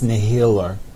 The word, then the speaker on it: Nihilur: Nilhinur. Nilhilur. Can't say it. Nilhilur is the one that does that, but I'm pretty sure they just don't need eyes. Like, they can see. – Yeah, it's pronounced Nihilur.